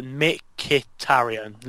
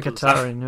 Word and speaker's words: Mkhitaryan: It's not hard. Mkhitaryan. Literally simple. – Mkhitaryan, yeah. – I always want to say it like the K is silent for some reason every time I look at it, and then I just get confused.